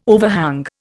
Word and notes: overhang: Indian

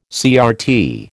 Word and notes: CRT: American English

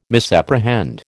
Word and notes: misapprehend: American English

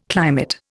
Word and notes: climate: Indian

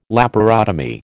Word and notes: laparotomy: American English

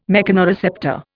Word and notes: mechanoreceptor: Indian

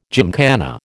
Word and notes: gymkhana: American English

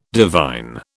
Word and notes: divine: British English

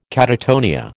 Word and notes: catatonia: American English

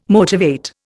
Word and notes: motivate: Indian

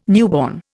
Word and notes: newborn: Indian

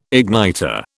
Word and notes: igniter: British English

